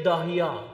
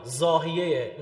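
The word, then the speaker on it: ضاحیه: داهیا زاهیه اینا زاد عربی رو د تلفظ میکنن تو انگلیسی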